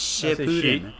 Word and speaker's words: Shippuden: You don't say sheet. It's shit. It's Shippuden.